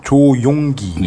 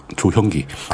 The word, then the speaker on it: 조용기: 발음을 잘해주세요. 조용기. 예. 뭐처럼 들리셨어요? 조형기.